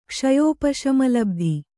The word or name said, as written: ಕ್ಷಯೋಪಶಮಲಬ್ಧಿ